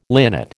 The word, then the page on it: linnet: American English